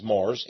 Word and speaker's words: Marrs: T-E-X-E. And the last name is Mars, M-A-R-R-S.